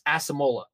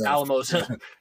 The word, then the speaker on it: Alamosa: was pronounced Asamola, because my father refuses to pronounce it Alamosa.